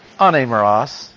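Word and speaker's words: anemeros (ἀνήμερος): So they slip in an N sound, anemeros, and it just makes it easier to pronounce.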